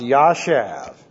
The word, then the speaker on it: jashab: J-A-S-H-A-B. Pronounced Jashav.